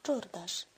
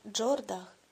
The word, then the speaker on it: Джордаш: Вимовляла повільно, мелодійним голосом, так, як йому подобалося. Джордаш. М'яко, майже музично. А не якийсь там Джордах, чи гірше того, як вимовляє дехто. Джодейк або Джордек.